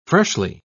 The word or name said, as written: freshly